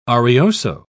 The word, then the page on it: arioso: British English